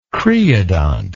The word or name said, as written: creodont